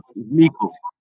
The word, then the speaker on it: Meikle: Meikle.